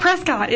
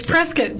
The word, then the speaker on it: Prescott: but it's not Prescott. It's Prescott.